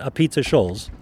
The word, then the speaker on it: Apizza Scholls: You pronounce it Apizza Scholls.